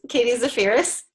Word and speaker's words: Katie Zaferes: Katie Zaferes.